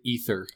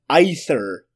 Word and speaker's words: aether: This is A-E-T-H-E-R. – Ether. – Yeah, which is pronounced either, but they don't say it like either. – That. – No, they say ether the whole movie.